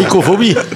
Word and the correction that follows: nyctophobie: Ça s'écrit N-Y-C-T-O-phobie. – Ah oui, nyctophobie.